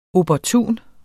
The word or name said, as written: opportun